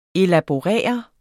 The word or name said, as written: elaborere